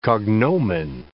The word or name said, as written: cognomen